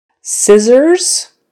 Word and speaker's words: scissors: All right, so now on to the words that are not as frequent, and those are the SSs where you pronounce the SS as a Z. There's just a few common ones. Scissors, possess, dissolve, dessert.